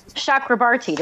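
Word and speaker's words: Chakrabarti: Chakrabarty, did I say that right? – Nailed it.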